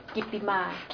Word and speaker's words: Kittima: Kittima, K-I-T-T-I-M-A, K-A-M-R-A-I-T-H-O-N-D.